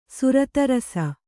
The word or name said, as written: ಸುರತರಸ